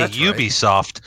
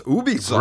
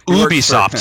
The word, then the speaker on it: Ubisoft: Some say Ubisoft, right. But that would be incorrect as I was informed. – So we get inside scoop on how to actually pronounce it. So it's Ubisoft. – Right. Ubisoft, since they are a French company.